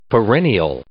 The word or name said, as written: perennial